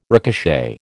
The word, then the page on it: ricochet: American English